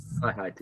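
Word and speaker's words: Große Freiheit: And it was called the, I don't know if I'm pronouncing it right, the Grossfreiheit. – Freiheit, yeah.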